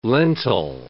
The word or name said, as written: lentil